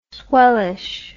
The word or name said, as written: swellish